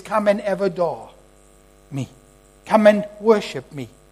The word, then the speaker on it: avodah: That word, work, in the Hebrew, is the same word, I'll spell it to you, it's called avodah. A-V-O-D-A-H.